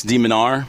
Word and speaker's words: de Minaur: How do you say this guy's name? Damar? – I would say, let me see it. Alex de Minaur.